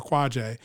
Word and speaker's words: Quajay: And you're like, you're Quajay.